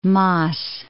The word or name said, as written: Maas